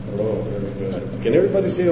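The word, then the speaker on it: Rho: And that would be like the, it's pronounced like if you were pronouncing in her, the word her, the H and the R together. Row.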